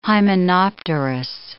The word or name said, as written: hymenopterous